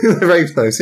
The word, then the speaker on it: Ravnos: It's actually pronounced Ravnar. – Ravnos. – Ravnos, yes.